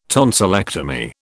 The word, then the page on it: tonsillectomy: British English